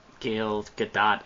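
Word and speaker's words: Gal Gadot: It also does not hurt that, you know, Gail Gadot, I think is actually how you say her name, because she's not French, she's Israeli, which I didn't know until I watched her in an interview say people keep saying her name wrong, but she is a very gorgeous woman.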